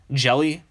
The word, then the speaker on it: Hjelle: Next guy is Sean Jelly. His name is spelled HJELLE.